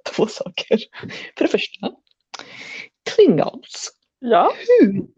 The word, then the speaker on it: Klingons: Alltså nej, nej, nej, nej, nej. uttala det inte på svenska. Alltså visst, okay, okay. eller fine, några säger klingoner. Klingoner, det låter så sjukt lame, eller hur?